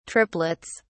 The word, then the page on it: triplets: British English